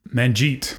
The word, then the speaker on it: Manjit: I'm sorry, but I think it's Manjit. It's M-A-N-J-I-T. – Manjit.